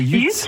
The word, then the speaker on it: Yutz: Ah d'accord, non, j'avais dit peut-être que c'était en Belgique, mais ça s'écrit comment? – J'ai compris, ça s'écrit UTZ? – Alors c'est YUTZ.